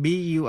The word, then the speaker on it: bury: Động từ bury, âm u. Trong bury đọc như e. To bury nghĩa là chôn. Burial, B-U-R-I-A-L là lễ an táng.